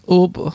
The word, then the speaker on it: Uber: Das heißt Uber da, das ist anders ausgesprochen. – Wie wird es ausgesprochen? – Uber. Du musst ein bisschen weinen dabei.